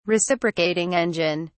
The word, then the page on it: reciprocating engine: British English